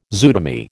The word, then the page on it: zootomy: American English